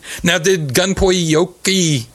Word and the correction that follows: Gunpei Yokoi: The prostitution hotels might have done better if Gunpei Yokoi worked on them.